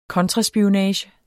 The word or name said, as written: kontraspionage